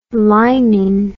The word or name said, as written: linin